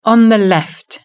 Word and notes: on the left: British English

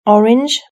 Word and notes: orange: British English

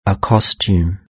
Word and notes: a costume: British English

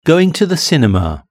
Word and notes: going to the cinema: British English

